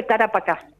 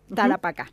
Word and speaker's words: Tarapacá: Bueno, pero ahora, desde 1999, pertenece al grupo San Pedro de Talapac. No sé si lo estoy pronunciando bien. – Viña San Pedro de Tarapacá. – Tarapacá, bien.